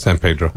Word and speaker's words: San Pedro: San Pedro.